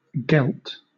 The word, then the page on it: gelt: British English